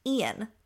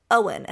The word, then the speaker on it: Eoin: But I will say that it was good that I listened to it on audio because her grandfather's name is spelled E-O-I-N. And when I was reading it, I thought I was being very clever in discerning that it is pronounced Ian because I thought that's how maybe the Irish spelled Ian. And as soon as she started reading, she pronounced it Owen.